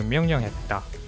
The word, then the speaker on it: 명령: By the way, 명령 is spelled out with a 르, but it sounds like a 니은 because it follows the sound 니은. So 명령 instead of 명령.